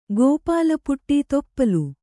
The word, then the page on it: ತಿಪ್ಪಲಿ: tippali